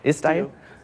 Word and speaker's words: Istio: How do you pronounce that? Istio. Envoy.